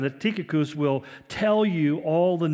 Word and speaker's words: Tychicus: But like the letter to the Philippians, it was delivered by, remember, Tychicus, or Tychicus, as it's pronounced correctly, and also by a man by the name of Onesimus.